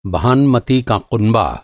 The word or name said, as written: भानमती